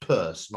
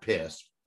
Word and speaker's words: Peirce: Sanders Peirce, P-E-I-R-S-R-C-E, which is weirdly pronounced Peirce, not Pierce, who's probably the greatest American logician of the 19th century.